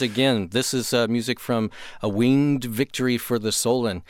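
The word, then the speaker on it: A Winged Victory for the Sullen: This is music from A Winged Victory for the Solen.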